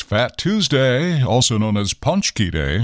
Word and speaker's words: paczki: Okay, it's spelled P-A-C-Z-K-I, magically pronounced Punchki.